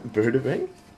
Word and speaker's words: Bruderheim: Established in 2012, we have travelled from one end of the proposed pipeline's route to the other, from, how do you say that, Bruderheim.